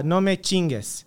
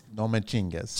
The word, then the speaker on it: No me chingues: How do you say it again? – No me chingues. – No me chingues.